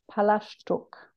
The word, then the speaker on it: Palaszczuk: Can you please pronounce Annastacia's surname in the traditional Polish way? – Palaszczuk. – Yes, that's right.